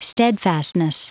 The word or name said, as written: steadfastness